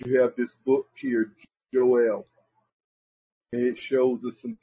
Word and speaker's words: Joel: We're back in the book of Joel, and again, it's Joel, Yoel, the way it would really be pronounced.